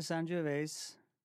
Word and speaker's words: Sangiovese: I like the Sangiovese. – I like the way you say it, too. Thank you. – Thank you for the correct pronunciation.